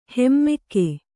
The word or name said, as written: ಹಮ್ಮುಗೆ